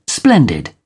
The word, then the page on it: splendid: British English